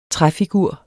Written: træfigur

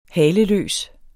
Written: haleløs

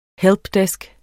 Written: helpdesk

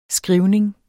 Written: skrivning

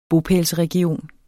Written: bopælsregion

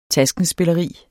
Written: taskenspilleri

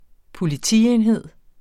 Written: Politienhed

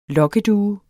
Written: lokkedue

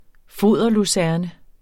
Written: foderlucerne

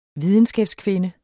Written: videnskabskvinde

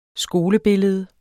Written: skolebillede

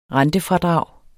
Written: rentefradrag

